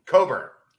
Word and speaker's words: Cockburn: Oh, with Kofi Cockburn. – Coburn. – Cockburn is the way that's spelled.